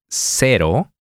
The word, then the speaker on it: cero: The Spanish R can be pronounced in two different ways. To illustrate this, we are going to use two different words that vary in just one sound. Cero. – Zero. – Cerro. – A hill.